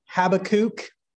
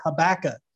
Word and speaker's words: Habakkuk: Habakkuk, or as I've heard some say, Habakkuk, or as I learned growing up in West Odessa, we just dropped off the last K and called it Habakkuk.